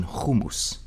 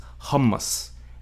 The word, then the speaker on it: hummus: As a child, I was taught the Hebrew pronunciation hummus. Later in life, I befriended a Palestinian-American woman who would shudder if she heard it said that way. It was hummus, and making the pronunciation Hebrew